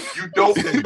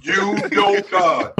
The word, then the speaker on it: Udoka: It's pronounced exactly the way it's spelled. Udoka. – Goku. – Udoka. That's the way it's pronounced.